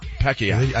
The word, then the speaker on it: Pacquiao: Pacquiao.